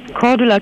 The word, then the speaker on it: Cordula: Cordula Kienes.